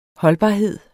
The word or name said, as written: holdbarhed